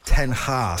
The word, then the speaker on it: ten Hag: Erik Ten Hag.